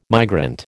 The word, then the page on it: migrant: American English